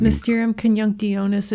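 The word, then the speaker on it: Mysterium Coniunctionis: Mysterium Conjunctionis.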